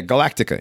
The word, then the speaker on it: Galactica: Yeah, Galactica. Yeah, I was expecting you to say it wrong again. I was prepared to yeah. No, yes, you got it right that time.